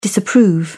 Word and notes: disapprove: British English

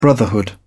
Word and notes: brotherhood: British English

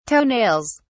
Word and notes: toenails: British English

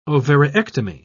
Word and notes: ovariectomy: British English

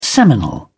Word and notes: seminal: British English

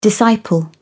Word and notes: disciple: British English

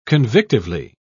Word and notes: convictively: British English

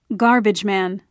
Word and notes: garbageman: British English

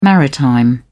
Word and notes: maritime: British English

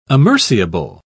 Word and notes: amerciable: British English

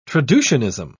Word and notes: traducianism: British English

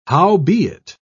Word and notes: howbeit: British English

